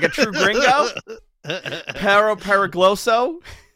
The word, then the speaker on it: Perro Peligroso: How do you say this last name? – Perro Peligroso. – Thank you. Perro Peligroso.